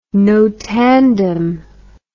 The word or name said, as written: notandum